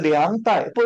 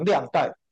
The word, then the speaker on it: 粮袋: 他的粮袋不要把它念成量袋，粮袋是来用粮的，这是有属灵含义。